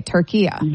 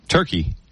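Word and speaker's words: Türkiye: Okay, Turkiya. – There you go. That's perfect. That's how it's pronounced. It's always been pronounced that in Arabic language. – Okay. – It's a short A at the end, not the long A.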